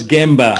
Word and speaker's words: Gemba: otherwise known as the Jumbo process innovation, if I'm pronouncing that right. Can you tell us a little bit more about this concept, and how can other organizations actually can benefit from that? – Sure. So it's Gemba, actually.